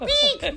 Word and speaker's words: wheek: You're making it more like an oink. It's more like a weak. That's what they call it. – Weak. – They spell it W-H-E-E-K.